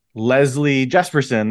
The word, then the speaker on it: Leslie Jespersen: I'm so excited today to welcome Leslie Jespersen.